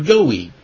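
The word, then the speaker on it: goy: This is the Hebrew word goi, is how it's pronounced.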